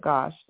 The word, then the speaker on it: Gosch: And to quote Christopher again, as for my abuse story, I was Johnny Gosch'd, attempt kidnapped in the same city as Johnny Gosch. But this was in 1976, nearly 8 years before, I hope I'm saying it, not Gosch, but Gosch.